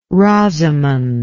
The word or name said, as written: rosamund